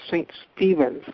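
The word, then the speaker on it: St. Stephen: Also, the station in Bishopville on 1360 is actually on 1380, and you pronounce that town in South Carolina St. Stephens, like the name Stephen.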